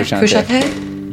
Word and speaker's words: Frusciante: Frusciante.